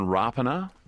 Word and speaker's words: Rapana: We've learned in recent weeks that the actual correct pronunciation is Jordan Rapana.